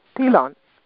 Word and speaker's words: Pilon: Actually, it's Pilon. – Pilon. – Mm-hmm. Yeah, okay. The N is more silent. It's like a oh.